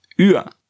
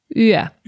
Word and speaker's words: una: la N intervocàlica no es pronuncie. I llavors l'article una, l'article indefinit una, és ua. – Ua.